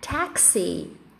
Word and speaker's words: taxi: É, então, na verdade, a pronúncia correta da palavra táxi em inglês é taxi. Taxi. A gente vai fazer esse som de E no A, mas a gente fala o I.